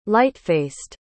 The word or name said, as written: lightfaced